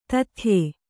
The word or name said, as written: ದೈತೇಯ